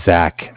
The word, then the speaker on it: Zach: My name is Zach, spelled Z-A-C-H. – Uh-huh. – And I'm from Bank of Cardiff. Cardiff is spelled C-A-R-D-I-F-F.